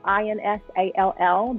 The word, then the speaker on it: Insall: And my last name is spelled Insall. There is no T. Everyone seems to want to put that T in there.